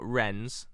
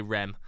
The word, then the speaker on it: Reims: It's spelt R-E-I-M-S. It's not Rennes, though. – No, it's Rennes. – I'm not going to say that. I'm going to say Rem because I'm not that competent.